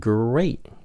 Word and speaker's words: great: Tại chữ T của cái chữ great nó nằm trên giữa chữ A với lại chữ O. Cho nên người Mỹ hay phát âm nó là chữ D lắm, âm D. Ví dụ cái chữ water là nước.